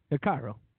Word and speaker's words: Hikaru: Yeah, Hikaru.